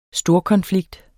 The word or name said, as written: storkonflikt